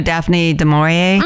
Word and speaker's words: Daphne du Maurier: You know, Daphne du Maurier? – Mm-hmm.